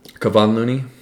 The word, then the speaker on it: Kevon Looney: You know, he's kind of he's Kevin Looney. – Kevon Looney. – That's it. – Terry Rozier. – I said Kevin. – No. Yeah, you said Kevin.